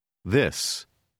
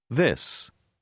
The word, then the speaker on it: this: Prononcez la dernière lettre comme s. – This. This.